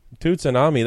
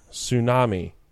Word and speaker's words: tsunami: Yeah, tsunami. – No. – The T is silent.